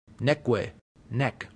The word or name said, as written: neque